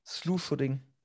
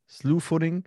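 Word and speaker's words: slew-footing: Wenn man, wie wird es denn ausgesprochen? Sleeve Footing? Slew Footing. Slew Footing.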